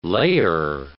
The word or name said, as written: layer